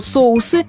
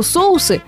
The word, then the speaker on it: соусы: Некоторые словари допускают использование и того, и другого варианта при условии, что соусы – литературная норма, а соуса – просторечие. К тому же многие филологи считают, что соусы с ударением на последнем слоге являются проявлением профессионального жаргона.